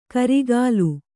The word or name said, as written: ಗೋರಿಕಲ್